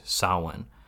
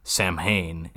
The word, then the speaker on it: Samhain: Maybe it's because we have an Irish actor here in Dan O'Hurley, who we haven't gotten to, but he pronounces the word correctly, Samhain. Donald Pleasance in Halloween 2 looks at that word written on a chalkboard and is like, Samhain. And it's like, no, fuck you.